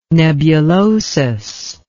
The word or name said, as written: nebulosus